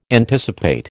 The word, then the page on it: anticipate: American English